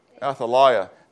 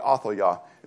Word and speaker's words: Athaliah: And so that's probably Athaliah is probably how you'd really say her name. We say Athaliah. And I said it for some reason that when he first said it, I went, Athaliah.